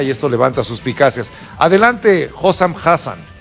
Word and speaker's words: Hossam Hassan: Vamos a escuchar al entrenador egipcio, Hossam Hassan o algo así. – Hossam Hassan. – Hossam Hassan, disculpe.